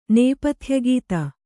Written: ನೇಪಥ್ಯಗೀತ

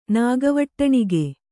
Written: ನಾಗವಟ್ಟಣಿಗೆ

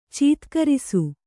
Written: ಚೀತ್ಕರಿಸು

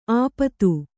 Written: ಆಪಾತ

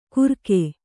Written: ಗುರಕೆ

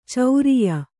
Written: ಚರಿಯ

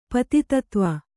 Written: ಪತಿತತ್ವ

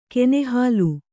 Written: ಕೆನೆಹಾಲು

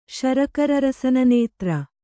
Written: ಶರಕರರಸನನೇತ್ರ

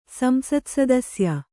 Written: ಸಂಸತ್ಸದಸ್ಯ

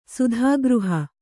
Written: ಸುಧಾಗೃಹ

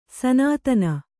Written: ಸನಾತನ

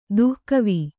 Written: ದಿಖಾವು

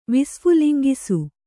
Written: ವಿಸ್ಫುಲಿಂಗಿಸು

